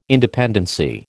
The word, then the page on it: independency: American English